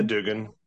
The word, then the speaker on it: Doogan: Yeah, Dugan. D-O-O-G-A-N. It's a Scottish name.